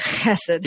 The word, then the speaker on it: hesed: I can't say it correctly in the Hebrew. It's like hesed. – Yeah. You got to get you got to clear your throat a little bit.